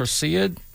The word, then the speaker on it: Perseid: Perseid? – Perseid.